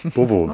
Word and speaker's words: Beauvau: Das Hotel Beauvau, sprich, das habe ich das richtig ausgesprochen? – Ja, total gut. Beauvau.